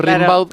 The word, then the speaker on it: Rimbaud: Como se pronuncia Gambó, pero es Rimbaud.